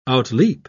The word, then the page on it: outleap: British English